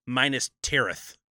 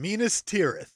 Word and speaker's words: Minas Tirith: Minas Tirith. – That's what I said, Minas Tirith. I keep saying minus instead of menus, but now I know it rhymes with penis, so we got it.